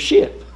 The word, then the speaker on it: schip: Our current spelling, though, comes from the Dutch word for the same thing, which is spelt S-C-H-I-P. And if you pronounce that, you would come up with ship.